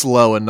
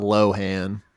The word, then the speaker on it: Lohan: It's Lohan, not Lohan. That's just like dumb white people shit.